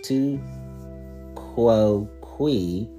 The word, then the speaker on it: tu quoque: In the world of religion, I experienced the logical fallacy of tu quoque, pronounced tu quoque, pronounced tu quoque. I corrected myself.